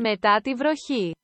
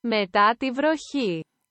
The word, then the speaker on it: Μετά τη βροχή: It is pronounced, according to Google Translate, Μετά τη βροχή.